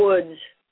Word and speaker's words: Wood: So I'll start with the one guest I know how to say her name, Sherry Wood, or Woods.